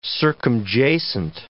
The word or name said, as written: circumjacent